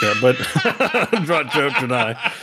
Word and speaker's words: Draenei: Draenei. Yeah, I'm saying that right.